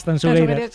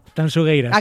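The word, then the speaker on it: Tanxugueiras: A ver, repítemelo, como tengo que pronunciarlo bien. – Tanzugueiras. – Sí, esto me lo corrige Fermina, Agustín, mucho a Marta, por favor. Digo, mira que yo siempre pronuncio. A ver, otra vez, dímelo. – Tanzugueiras. – Acaba de deshacerse la manifestación a las puertas de la Gran Vía, de lo bien que lo has dicho.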